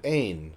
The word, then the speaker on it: Ain: And a pronunciation guide said Ain, Scottish.